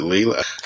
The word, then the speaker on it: Lila: Is it Lila? – Lila. – I think it's Lila. – It's an Italian name, and yeah, they pronounce the I as E.